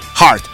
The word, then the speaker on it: Heart: Yo recuerdo este, esta banda, eh, Heart, porque se dice así, Pepe, Heart.